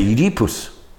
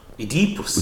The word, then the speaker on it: Oedipus: We all had, we all had to say Oedipus. Oedipus. Oedipus. Okay. Because that's how the Greeks say it. All right. – It's not Oedipus. – It's Oedipus. Okay. The emphasis is on the e in, in, in Greek in Greek and Russian.